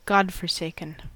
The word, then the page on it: godforsaken: American English